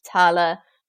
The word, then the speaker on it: tail: So the word tail was pronounced tala, the word root was pronounced rota, and it also impacted some consonants.